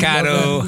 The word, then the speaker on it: Mikado: Mikado, Hot Mikado, rhymes with baked potato.